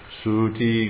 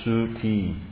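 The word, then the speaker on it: sūti: Suti grihe. Is that the verses up there? Huh? Well, it's long. It should be Suti. Long U, long I. Suti. Suti grihe.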